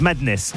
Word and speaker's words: MNDS: ce soir on découvre ensemble MNDS, ça se prononce Madness, c'est français, je vous assure.